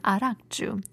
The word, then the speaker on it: arakju: So in the Hwanghae region, soju is still often uh, referred to its Mongolian pronunciation arakju.